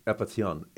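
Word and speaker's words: apeithon: It's epithion, A-P-E-I-T-H, long O-N.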